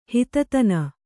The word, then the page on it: ಹಿತತನ: kannada